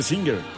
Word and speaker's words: Singer: Y la pronunciación oficial debía ser Singer. – Sí, claro. – Sí. Singer. Sí, es que sí era. Sí. Pero decíamos Singer siempre sí, en los sí, montañeritos.